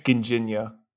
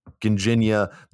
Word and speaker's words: Ginjinha: Go get a thing of, how do you pronounce it? Ginjan? – I think it's Ginjinha. – Ginjinha, the cherry liqueur from Portugal.